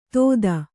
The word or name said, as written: ತೊದ